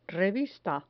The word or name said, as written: revista